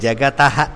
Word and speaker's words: जगतः: जगतः जगतो मीन्स टू प्रोनाउंस इट एस जगतः